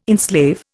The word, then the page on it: enslave: Indian